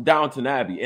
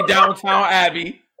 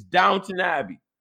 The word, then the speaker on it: Downton Abbey: I know you're not watching Downton Abbey, and I said it right. I ain't say Downtown Abbey like y'all. Okay, it's not Downtown Abbey. It's Downton Abbey.